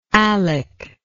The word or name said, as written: Alick